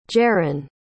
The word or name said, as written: jarin